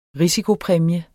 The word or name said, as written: risikopræmie